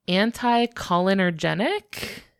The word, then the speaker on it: anticholinergic: It was titled Hexing Herbs in Ethnobotanical Perspective, a Historical Review of the Uses of, and you might need to help me with the pronunciation on this, anticholinergic. Have you heard this word before? – Yes, that's how I would have said it. – That's absolutely correct.